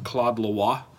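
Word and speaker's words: Claude Le Roy: Okay, I'm pretty sure this name is in French pronounced Claude Lois. – Okay. – It looks like it's Claude Leroy because it's R-O-Y.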